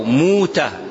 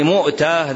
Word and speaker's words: مؤتة: 3 أقوال: أنها بضم الميم وهمز الواو، مؤتة، وبه جزم ثعلب والجوهري وابن فارس، والثاني بضم الميم وسكون الواو، بضم الميم وسكون الواو، موتة بغير همز، عزاه ابن حجر لأكثر الرواة، والثالث قيل بالوجهين، والثالث قيل بالوجهين، يعني مؤتة ذلك الموضع، وهو موجود بالأردن، ينطق بالهمز تارة وبغيرها تارة أخرى، لكن ينبغي مراعاة أنه أن كلمة موتة معناها الجنون في اللغة العربية، أن كلمة موتة تأتي بمعنى الجنون في اللغة العربية.